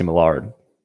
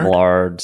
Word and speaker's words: Millard: Millard. – Millard? – Scott Millard, Scott C. Millard.